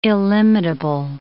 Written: illimitable

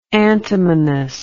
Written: antimonous

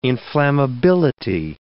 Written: inflammability

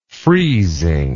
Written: friezing

